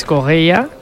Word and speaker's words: Correia: A pronunciarlo bien, Stassin, como nos han apuntado, y Félix Correia, como dice José, con muy buena pronunciación portuguesa.